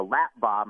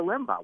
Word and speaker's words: Limbaugh: Well, I mispronounced it. I said the lap bar. my parents tell me, but the radio was the limbo.